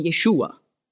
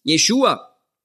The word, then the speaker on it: Yeshua: Dass man einfach hart bleiben und man spricht von Yeshua. Oder? Das ist einfach die hebräische Aussprache für Jesus. Yeshua.